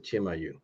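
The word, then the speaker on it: Chimayó: It's Chimayo, and they pronounce it Chimayu.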